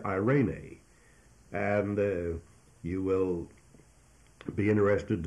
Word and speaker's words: eirene: The word peace in Greek is the word eirene, E-I-R-E-N-E. It's pronounced eirene, E-I-R-E-N-E, eirene. And this is a noun that comes from the verb eiro, E-I-R-O, and the word eiro means to join.